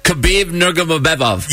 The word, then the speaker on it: Khabib Nurmagomedov: Khabib Nurmagomedov. – Yes. – Oh, wow. – Yes. – Rolls off the tongue. – Khabib Nurmagomedov.